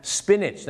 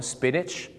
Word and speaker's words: spinach: ไม่ใช่สปีแนทหรือสปีนาทนะฮะ spinach นั่นเองครับออกเสียงว่า spinach